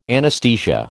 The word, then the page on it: anaesthesia: American English